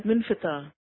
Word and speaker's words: منفتح: تو یہ جس فرعون کی بات ہو رہی ہے، اس کا نام ہے منفتح. اس کے سپیلنگ میں بول دیتی ہوں، M E R N E T H I T H, منفتح. اردو میں عموماً منفتح لکھا جاتا ہے، آر نہیں بولا جاتا.